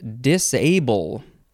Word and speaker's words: disable: Okay, the first word in this episode is disable. Disable, the A is emphasized, and you can pronounce it dis or disable. Disable, D-I-S-A-B-L-E, transitive verb from the 15th century.